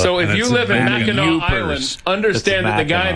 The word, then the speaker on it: Mackinac: It's Mackinac.